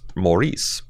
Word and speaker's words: Maurice: The English version of Maurice is pronounced like Morris Chestnut's name.